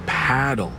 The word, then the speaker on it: padel: No, that's paddle. Rhymes with saddle.